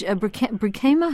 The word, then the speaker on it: Brinkema: Well, tell us about Judge Brinkema. How do you pronounce Brinkema.